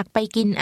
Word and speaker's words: ไป: ดิฉันอยากไปกินอาหารกลางวันค่ะไปไปดิฉันอยากไปกินอาหารกลางวันค่ะ The word for go is pronounced with a mid-level tone. Say, go.